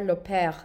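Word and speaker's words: L'Aupaire: L e, yukarıdan virgül A U P A I R E diye yazılıyor arkadaşlar. Loper diye okunuyor Fransızca.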